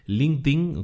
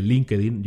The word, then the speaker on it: LinkedIn: Otras redes sociales que te pueden interesar, LinkedIn, LinkedIn, como se pronuncia, pero en español mucha gente dice LinkedIn, yo también.